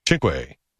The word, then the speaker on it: cinque: Cinque? How do you say that in Italian? – Yes. – Cinque. – Is that how you say it in Italian? – Italian, yep.